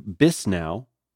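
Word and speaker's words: Bisnow: BizNow, I guess it's B-I-S-N-O-W, BizNow, that's how I should pronounce it, right?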